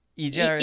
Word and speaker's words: Hydra: Yes, we went to I think it's I want to say Hydra, but I think it's pronounced Idra.